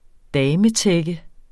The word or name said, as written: dametække